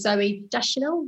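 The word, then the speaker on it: Zooey Deschanel: And it was, the main girl was Zoe Deschanel.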